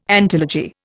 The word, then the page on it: antilogy: Indian